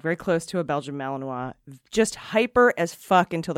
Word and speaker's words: Malinois: Oh, it even tells me how to say it. Malinois.